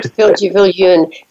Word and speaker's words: Viljoen: And they can also find me on their internet if they just search for my name, Edward Viljoen. It will come up quickly because it's such an unusual name in America. They'll find me. – And it's Viljoen, so it's pronounced differently from the spelling.